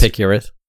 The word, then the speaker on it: Epicurus: How do you actually pronounce his name? Because obviously it's not Curious. Is it Epicurus? – Yeah, it's Epicurious. – Epicurus. Do not spoil what you have by desiring what you have not.